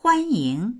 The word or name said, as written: huan1ying2